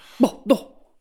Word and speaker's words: Bordeaux: Bordeaux.